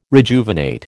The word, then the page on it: rejuvenate: American English